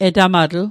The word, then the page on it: etamatl: Mi'gmaq